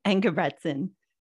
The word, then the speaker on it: Engebretson: Engebretson.